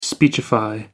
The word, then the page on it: speechify: American English